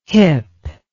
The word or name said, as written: hyp